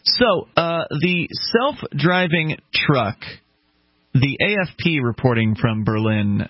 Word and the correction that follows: Berlin: Berlin. I messed it up. It's Berlin. Germany, and Berlin, New Hampshire. – Right, I'm so used to pronouncing the New Hampshire version of it.